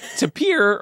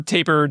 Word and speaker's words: tapir: And the adorable odd-toed, worm-nosed ungulate tapir or taper, depending on your flavor of pronunciation.